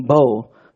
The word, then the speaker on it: Bo: You know, we named him Bo, which rhymes with no.